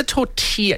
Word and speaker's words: tortilla: Do you say tortilla or tortilla? – Tortilla. – Tortilla. Tortilla. – I think that's how you say it, right? – It's a double L silent. – Yes. – Double L in, is it Mexican? – Spanish? – Spanish.